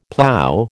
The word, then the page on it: plow: American English